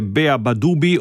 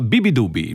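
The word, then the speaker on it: beabadoobee: Vi ho annunciato che si tratta di Pylon, il nuovo disco di questo artista, il cui nome si scrive Beabadubi. Si potrebbe leggere Beabadubi o Beabadubi, ma no, lei lo pronuncia Bibidubi.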